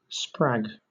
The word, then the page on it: sprag: British English